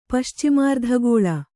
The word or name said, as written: ಪಶ್ಚಿಮಾರ್ಧಗೋಳ